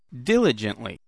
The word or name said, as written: diligently